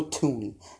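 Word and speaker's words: Thuney: Also, to Patriots guard Joe Thurney is set to be a free agent. – Tooney, say it right. It's not Thurney. It's Joe Tooney.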